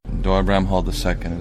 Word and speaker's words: Doyle Bramhall II: Doyle Bramhall II is the correct pronunciation of my name.